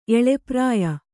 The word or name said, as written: ಎಳೆಪ್ರಾಯ